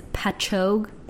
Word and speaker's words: Patchogue: Colette Catherine Stevenson was born in, you might know how to say this, Patchogue.